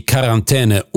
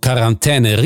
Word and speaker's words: Quarantäne: Dass wir ausgerechnet die Quarantäne unitalienisch aussprechen, hat einen einfachen Grund. Wir haben die Quarantäne von den Franzosen entlehnt, aus Quarantaine, einer Ableitung von Quarante, 40. Den einzigen Vorwurf, den wir uns machen dürfen, ist die Aussprache des im Französischen verschluckten finalen E.